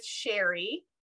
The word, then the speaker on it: Cheri: And you can follow me on Instagram at Clarity with Sherry. And my first name is spelled C-H-E-R-I. So that's a little different.